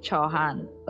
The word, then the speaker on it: Chauhan: I don't know if I pronounced your name correctly. – Yeah, it's Chauhan. – Okay, Chauhan.